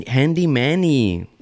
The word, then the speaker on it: Manny: Handy Mandy? It's Handy Man, Handy Manny. – No, you said it wrong again. – I said Handy Mandy and she's like, it's Handy Manny.